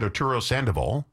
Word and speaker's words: Arturo Sandoval: Arturo Sandoval.